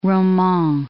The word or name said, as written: Roman